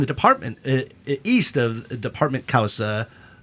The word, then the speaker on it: Cauca: How do you say C-A-U-C-A, in Espanol? Causa, I would Causa.